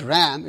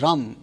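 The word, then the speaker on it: Ram (रं): They are written as, you know, in Sanskrit we write it as R-A-M, for example, but it's pronounced almost like R-U-M. So it's pronounced not Ram, it's pronounced as Ram, R-U-M, Ram, okay?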